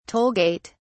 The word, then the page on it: toll gate: British English